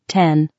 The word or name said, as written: 10